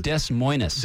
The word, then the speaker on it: Des Moines: It is northbound I-5 getting up through the Kent Desmonez Road. As we get up 516. Is that how you say it? – It's Des Moines. – Des Moines, not Des Moines.